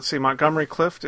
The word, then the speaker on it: Montgomery Clift: Well, I thought Montgomery Clift.